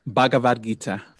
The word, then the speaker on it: Bhagavad Gita: You quoted the Bhagavad, okay, help me pronounce the ancient Hindu text. – Bhagavad Gita.